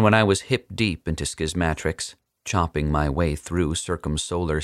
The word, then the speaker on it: Schismatrix: At last, I can formally tell a skeptical public that the title of Schismatrix is pronounced Schismatrix, with a short A, like schismatics, but with an R.